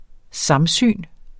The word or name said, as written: samsyn